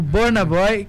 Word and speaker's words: Burna Boy: É, o Burna Boy, que se pronuncia Burna Boy.